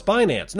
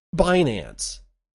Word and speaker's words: Binance: No, it's Binance. No, it's Binance. They actually had an argument in a press conference about how to pronounce Binance. That's how you pronounce Binance. It's pronounced Binance.